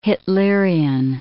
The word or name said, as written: Hitlerian